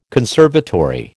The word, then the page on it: conservatory: American English